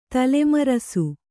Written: ತಲೆಮರಸು

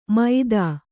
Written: ಮೈದಾ

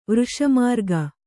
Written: ವೃಷಮಾರ್ಗ

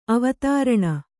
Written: ಅವಧಾರಣೆ

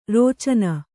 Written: ರಚನ

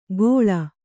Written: ಗೋಳ

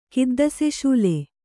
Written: ಕಿದ್ದಸೆಶೂಲೆ

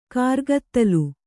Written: ಕರ್ಗತ್ತಲೆ

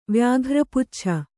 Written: ವ್ಯಾಘ್ರಪುಚ್ಛ

